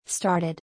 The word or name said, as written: Started